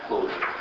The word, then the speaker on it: Clojure: Кто знает английский язык, там вообще-то должна быть буква S. А там J. Произносят точно так же. Да? Closure.